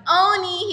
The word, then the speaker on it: Onyi: We have Onyi here from MiriEffect Skincare.